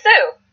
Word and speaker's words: Sioux: S-I-O-U-X. – Oh, Sioux. – Sioux. See, that does not spell. That does not go with how it spells.